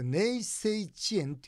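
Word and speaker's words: 寧静致遠: でこれ「寧静遅延」って読むんです丁寧の寧に静か、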